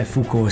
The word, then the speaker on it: Foucault: Non è Foucault, come ho detto, conosco il filosofo, ma è Foucault, Michel Foucault, la pronuncia che molti che conoscono il francese, quando ho pronunciato saranno rabbrividiti, però è Foucault, sì.